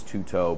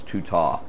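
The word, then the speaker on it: tuta (τοῦτο): Omicron in Greek, that O is pronounced as a short vowel. In English, we would see that and think it's tuto, but in Greek, that's pronounced short, so it's tuta.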